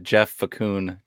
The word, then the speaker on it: Facun: That's conductor of the John Leonard hype train, Jeff Facun, to you, man.